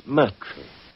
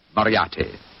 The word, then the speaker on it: Moriarty: My name is Murtry. – Oh, indeed. Uh, spelled M U R T R Y? – No. Dear me, I have so much trouble with my name. People will either misspell it or mispronounce it. I'm afraid I'll have to begin calling it the way it looks. M O R I A R T Y. Moriarty. Moriarty. I shall remember that name.